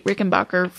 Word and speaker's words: Rickenbacker: I wanted to ask her about a complex, I think, restoration project involving a 1968 Rickenbacker 4001.